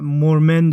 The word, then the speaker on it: مورمن: که من بارها تلفظش کردم مرمون و مورمن درسته